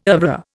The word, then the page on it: oeuvre: Indian